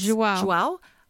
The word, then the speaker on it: João: João.